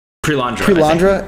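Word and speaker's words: Perelandra: Like Paralandra. Am I saying that right? – Prelandra, yeah.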